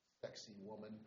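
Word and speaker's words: Sacsayhuamán: And just we were in Cusco underneath the hotel as they were Marriott, they found some of this another Inca fortress called Sexy Woman, but it's spelled different but pronounced real close.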